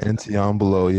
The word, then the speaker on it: Anteambulo: They had a term, Antimbulo, I know I'm massacring that word. – That is Antiambulo, yeah, something like yes. That. – See, there we go. English educated right there. Antiambulo, that is spelled Anteambulo.